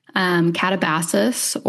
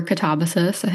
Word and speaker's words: Katabasis: Kuang's new book, Katabasis, or Katabasis. I think you can pronounce it either way.